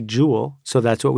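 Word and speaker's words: Joule: Well, let's get back to this idea of James Prescott Jowell or Jowell.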